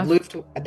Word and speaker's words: Luftwaffe: Yeah, Luftwaffe.